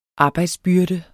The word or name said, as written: arbejdsbyrde